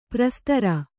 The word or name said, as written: ಪ್ರಸ್ತರ